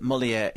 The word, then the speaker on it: Molière: At number 8, Molière, and Matthew says, Simon, could you tell Dr. Kermode that Molière is pronounced to rhyme with where? – How was I?